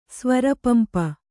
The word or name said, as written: ಸ್ವರಪಂಪ